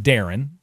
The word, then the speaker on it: Daron: We just find words that rhyme with my name, Daron, such as Sharon with Daron.